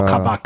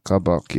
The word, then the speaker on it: Kabak: How do you pronounce that guy's name? – Oh, Kabak. Kab- Kabak.